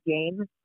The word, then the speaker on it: Game: Uh, and the last name is Game, like a board game.